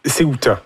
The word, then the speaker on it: Utah: C'est Utah.